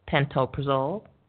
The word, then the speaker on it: pantoprazole: And looked at four of the most popular proton pump inhibitors, omeprazole, pantoprazole, lansoprazole, and esomeprazole.